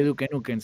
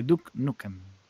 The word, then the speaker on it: Duke Nukem: No se dice Duke Nukem, se dice Duke Nukem.